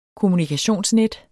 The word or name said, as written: kommunikationsnet